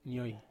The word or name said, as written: nioi